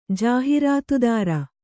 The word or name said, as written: ಜಾಹಿರಾತುದಾರ